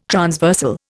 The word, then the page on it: transversal: Indian